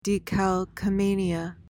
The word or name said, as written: decalcomania